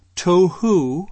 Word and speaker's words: tohu: And it's one Hebrew word, tohu, is how I pronounce it.